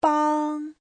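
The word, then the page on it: 帮: bang1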